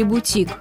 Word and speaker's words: бутик: Бутик вместе с такими словами, как парашют и жалюзи, пришел к нам из французского языка. Что мы знаем о французском? Правильно, ударение на последний слог. Бутик в русском языке не изменил свое французское звучание и ударение сохранилось.